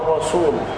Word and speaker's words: الرسول: أن الرسول يا إخوة هذه الكلمة مهمة جدا لأنها تنطق لحنا هكذا الرسول وده عندك قاعدة دائما المبتدئ يفخم السين من هذه الكلمة الرسول حتى في الأذان يقول أشهد أن محمدا رسول الله رسول خطأ رسول وشهدوا أن الرسول حق الرسول نعم